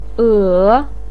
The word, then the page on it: e3: pinyin